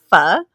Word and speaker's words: pho: It's pho. – Okay. For such a short word, it feels very difficult to pronunciate. – It does. It is. There's like several intonations in it.